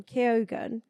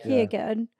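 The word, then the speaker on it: Keoghan: I think Barry Keogh, how did you say his name? Keogan. Yeah. Keogan.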